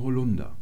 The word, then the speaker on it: Holunder: Zum Beispiel mit dem Holunder. Der wird genau wie die Veranda auf der zweiten Silbe betont, obwohl da vorne kein Präfix drin ist und obwohl der Holunder nicht aus dem Ausland entlehnt ist.